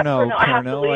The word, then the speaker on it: Pernod: Pernod. – Yeah, Pernod. – I guess you say. – Yeah. – You don't pronounce the D.